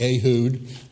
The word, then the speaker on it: Ehud: Oh, it's actually Ehud. That's the way they liked. I just remember pronunciations when we said in a class studying old language and stuff, but Ehud is the way they like to pronounce it in modern Israel.